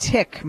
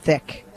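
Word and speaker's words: thicc: Oh, it's pronounced tick, Mom. It's pronounced tick, not thick.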